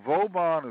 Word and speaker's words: Vauban: And there's another community that it's called Vauban, V-A-U-B-A-N, or some people pronounce it Vauba.